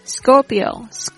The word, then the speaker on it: Scorpio: 接下来是天蝎座，天蝎座的同学呢是这个 Scorpio，它是这个阳历 10 月 23 号到 11 月 21 号。 Scorpio OK S-C-O-R-P-I-O Scorpio 在念这个字的时候，记得那个 C 那个 K 的发音要发 G 的声音，因为它是在这个 S 的轻音后面。 Scorpio，连那个 P 的声音都会有一点啵啵啵的声音。